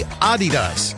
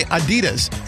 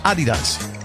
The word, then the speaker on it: Adidas: Americans pronounce Adidas wrong. It's actually supposed to be Adidas, with a slight emphasis on the first syllable. I first pronounced it. We all say Adidas, stressing the second, but it's a little bit on the first.